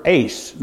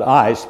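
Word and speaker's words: ace (εἰς): In the Liddell and Scott lexicon of 1843, the word ace, now that E-I in there is pronounced like the word eight, you know, eight, and that's ace. So that's, most people call that ice, but it's ace.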